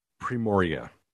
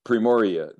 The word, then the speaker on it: Primorye: Primorye, is that how you pronounce it? – Primorye. – Primorye, the province.